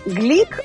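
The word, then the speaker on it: Glück: Луизе Глик, которую поначалу все называли Луиза Глюк, но потом выяснилось, что ее фамилия произносится все-таки как Глик, и сама она себя так называет.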